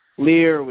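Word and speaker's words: Leer: Lear with 2 E's. Rhymes with queer.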